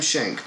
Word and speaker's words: Schenck: And I kept seeing Joseph M. Schnick, or Schenck. I'm sorry, I'm saying it wrong. Joseph M. Schenck, producer.